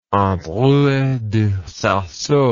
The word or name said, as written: Androuet du Cerceau